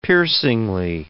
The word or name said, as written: piercingly